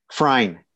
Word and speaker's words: Frein: Frein. – Frein. Okay. Yeah. Is that German? – Yeah, it's German. The E is silent.